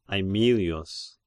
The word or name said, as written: Aemilius